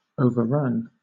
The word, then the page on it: overrun: British English